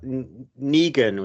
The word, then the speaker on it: Negan: Um, we got Negan, which is how you've told me you pronounce it, from The Walking Dead.